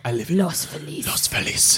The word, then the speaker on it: Los Feliz: what should be Los Feliz is Los Feliz.